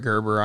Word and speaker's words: Gerber: We did get Brett Gerber on, and I missed that one, unfortunately. – Oh, my gosh. Hard G.